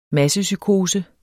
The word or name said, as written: massepsykose